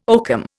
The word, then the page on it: oakum: Indian